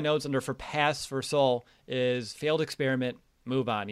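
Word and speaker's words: Sowell: Pass on Bradley Saul. His time is done. – Technically, isn't it Soul? – Soul. Well, I said Sal. Yeah. I was like, that's not right, Will.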